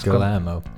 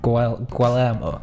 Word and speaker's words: Guillermo: How do you pronounce his name? Gamita Del Toro. – Guillermo Del Toro. – Yeah, how's his first name pronounced? – Guillermo. – Guillermo. Okay.